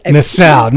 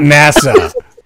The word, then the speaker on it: Nassau: And it was Nassau, is what I was thinking. – Oh, NASA? Bahamas? – Nassau. – Okay. I'll Nassau, not NASA.